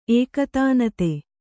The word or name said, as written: ಏಕತಾನತೆ